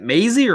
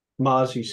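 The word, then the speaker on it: Mazi: Mate, is it Maisie or Mozzie? Maisie? – Mozzie Smith.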